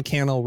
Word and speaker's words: Cannell: Sean Cannell rhymes with YouTube channel.